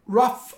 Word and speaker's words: Roth: Philip Roth, Roth, a pronúncia correta aqui, é também cancelado por parte do pessoal por misoginia, antissemitismo, ele é judeu.